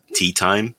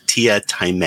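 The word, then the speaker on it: Teatime: And there's a character in there called himself Tia Time, but it's spelled Tea Time. So people keep calling him Tea Time. And he's like, no, it's Tia Time.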